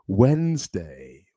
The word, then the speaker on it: Wednesday: Miércoles en inglés, Wednesday, Wednesday, pero no se dice Wednesday, no, Wednesday, Wednesday. Tiene como un toque de Z, ¿no? En Wednesday.